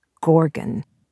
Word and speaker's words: gorgon: And I kept wanting to pronounce it gorgon, because that sounds more monstrous, but it's gorgon.